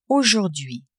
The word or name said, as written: aujourd'hui